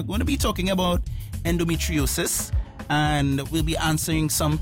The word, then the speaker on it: endometriosis: You pronounced endometriosis perfectly.